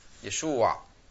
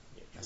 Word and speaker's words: Yeshua: Y la Ain final se pronuncia guturalmente. Muy, muy, muy abajo. Yeshua. Yeshua. Así se dice, Yeshua. Así se llama, Yeshua.